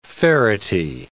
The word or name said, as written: ferrety